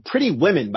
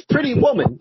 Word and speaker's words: Pretty Woman: Pretty Woman gets pronounced as Pretty Women by a lot of people. And it's like, what's going on here? has everyone gone mad? Like, it's Pretty Woman, like the song, Pretty Woman, yeah.